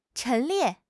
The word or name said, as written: 陈列